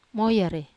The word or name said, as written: moyare